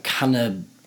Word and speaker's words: cannabinoids: So another thing that I've read about with it is, I always pronounce it incorrect, it's cannabinoids.